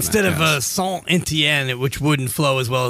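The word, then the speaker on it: Saint-Étienne: It's like Paris Saint-Germain, because it's a T going to a G. But if you have Etienne, then you pronounce a T, you say Saint-Etienne. So it depends on the vowel, and you pronounce that last.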